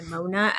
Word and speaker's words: Nimona: I did enjoy Nimona. Pneumonia. – She just said someone is going to mispronounce it as pneumonia.